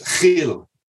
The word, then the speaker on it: Gil: It's the Jewish pronunciation. The Spanish pronunciation is Gil.